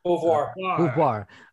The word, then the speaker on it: Beauvoir: By the way, Gene, I'm not sure how you pronounce his last name. Beauvoir. Beauvoir. Okay, so he played bass on Get All You Can Take, Under the Gun, and Thrills in the Night.